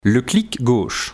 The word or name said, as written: le clic gauche